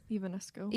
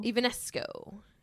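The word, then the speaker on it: Evanesco: Ivanesco. – Ivanesco.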